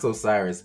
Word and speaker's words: Osiris: Oh yeah, Osiris or Siri? – It's Osiris. – It's Osiris. – It's pronounced Osiris, but he was awful.